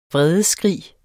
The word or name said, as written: vredesskrig